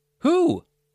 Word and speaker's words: who: The number two is spelled T-W-O, but it has the same pronunciation as two, T-O, and two, T-O-O. Two, two, two. They all have the same pronunciation. Number four, who? W-H-O has a silent W. Who? Who let the dogs out? Number five, hole. That's right, hole, W-H-O-L-E, W-H-O-L-E, hole, means all of something or entire, the entire thing or the whole thing.